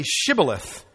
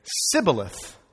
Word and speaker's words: Shibboleth: And if he said no, then they would say to him, then say Shibboleth. And he would say Sibboleth. In other words, they caught them by their accent. Thank you. They caught them by their accent, for he couldn't pronounce it right, and they figured out who the Ephraimites are.